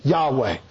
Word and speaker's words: Yahweh: The first is the covenantal name of God, the tetragrammaton, the four-lettered Hebrew title for God, YHWH, pronounced Yahweh, the sacred name.